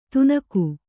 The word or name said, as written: ತುನಕು